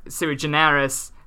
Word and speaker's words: sui generis: It is a weird sui generis, sui generis, I don't know why I put a hard G, pseudo holiday.